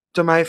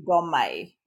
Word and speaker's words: Domme: Dom or Domme. It's okay. – Both pronunciations is fine.